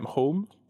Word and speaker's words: Holm: I hope I'm saying that right. H-O-L-M, Holm. – That's how I pronounce it, but I wouldn't want to speak for Orkadian. – On behalf of Orkney, I hope it's Holm. Holm in Orkney.